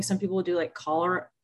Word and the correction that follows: chlorella: the infectious disease. I'm saying it wrong. The little green, Chlorella.